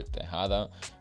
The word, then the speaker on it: Tejada: My name is Victor Tejada. For a lot of you guys that could pronounce it, it's T-E-J-A-D-A.